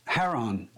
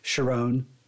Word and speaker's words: Charon: It's in Greek mythology, the ferryman who ferries the souls of the dead to the underworld, it's Charon, Charon, and then more of an English pronunciation of that is Charon. But in this, I did see, I found an interview with Lance Reddick, and he referred to it as Sharon, and and then they also mentioned it in John Wick 4.